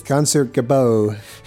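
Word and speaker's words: Concertgebouw: Concert Bigot or whatever it is. – Concert Gabot.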